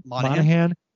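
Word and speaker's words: Monaghan: Monahan.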